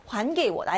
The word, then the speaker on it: 还给我: 금요일 전에 여기 하이라고 읽지 않고 환이라고 읽어야 된다고 했죠. 환给我, 나에게 돌려줘.